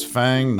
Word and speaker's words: FAANG: Still pronounce FANG, not FAANG.